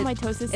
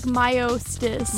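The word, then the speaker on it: Myosotis: No, it's not mitosis. – Is it like, mitosis? – It's like Myostis. – Myostis. It's spelled really strangely, but it's Myostis from the hit video game Demo.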